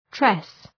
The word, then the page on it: tress: tres